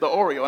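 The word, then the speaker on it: the Oreo (θεωρέω): And this Greek word is literally spelled like my favorite cookie, the Oreo. T-H-E-O-R-E-O, the Oreo.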